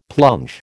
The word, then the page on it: plunge: American English